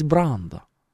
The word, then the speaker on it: Брандо: Марлон Брандо номер два, просто. И выходит знаток, говорит, ну, по-настоящему все-таки правильно говорить Брандо.